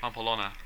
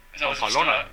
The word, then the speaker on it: Pamplona: David Bigging was gored by a bull in Pampalana. whatever in 2003. – Pamplona. – Yeah. Pamplona. Mr. Biggings, what a name.